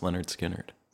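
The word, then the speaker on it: Lynyrd Skynyrd: And pronounced Leonard Skinner.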